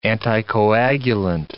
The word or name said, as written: anticoagulant